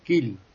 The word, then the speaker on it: Kiel: Por ejemplo, la IE, IE, sí. En alemán es I. Por ejemplo, Kiel, es la ciudad portuaria, es Kiel.